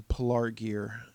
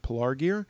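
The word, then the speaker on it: Pelargir: at Pilar Gear. I think Pelar Gear, I think, is a better pronunciation.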